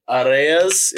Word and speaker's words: Arraez: Arraez.